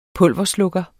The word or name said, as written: pulverslukker